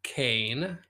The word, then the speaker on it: Kayne: This is Kayne, not Kanye.